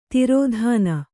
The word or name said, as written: ದೊರೆತನ